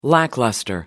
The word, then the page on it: lackluster: British English